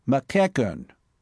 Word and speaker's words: McEachern: "'Say it again. Fill your lungs and enunciate slowly and clearly. – Be bell-like. Now. McEachern.